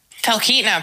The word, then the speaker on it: Talkeetna: Talkeetna, pretty close.